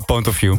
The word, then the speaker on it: Point of View: Ponet of You, zo heet het. Hoe moet ik het uitspreken eigenlijk? – Ja, doe maar Point of View. – Point of View is beter.